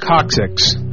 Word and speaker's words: coccyx: Says it's coccyx. Look, man, it's pronounced coccyx, apparently, and that's from our medical correspondent, Czar, the Surgeon General of Madhouse Radio.